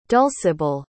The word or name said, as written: dulcibelle